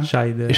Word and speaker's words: Scheider: mi hai insegnato che si pronuncia? Scheider, Scheider eh, che forse magari ve lo ricorderete come lo sceriffo protagonista dello scuola squalo.